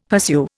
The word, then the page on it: pursue: Indian